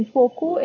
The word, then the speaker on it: Foucault: So he has written, Foucault is actually written as F-O-U-C-A-U-L-T, but then it is pronounced as Michael Foucault.